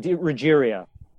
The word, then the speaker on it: DeRuggiero: DeRuggiero.